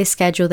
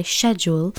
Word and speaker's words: schedule: We say schedule, they say schedule.